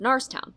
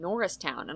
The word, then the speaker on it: Norristown: like my last name, Morris, but with an N. – Right. – Which is, of course, pronounced Narstown. – Right. – And it never even occurred to me to pronounce it Norristown until I heard a car dealership with a paid voice actor saying Norristown.